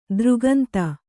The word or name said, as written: ದರಿಕಂದ